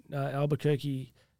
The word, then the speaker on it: Albuquerque: I spoke in Albuquerque.